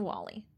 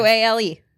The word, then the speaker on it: Wale: Maybe Parker is the only one that listened to this man on how to pronounce his name. He's like, it's actually Wale, but like, I've given up.